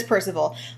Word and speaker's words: Parzival: P-A-R-Z-I-V-A-L. – Yeah, it's just the yeah, okay, so it is Percival.